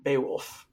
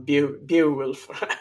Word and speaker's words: Beowulf: Beowulf, or I always pronounce that wrong, Beowulf.